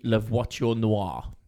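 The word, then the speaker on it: La Voiture Noire: So the most expensive car in the world is the Bugatti Le Voiture Noir.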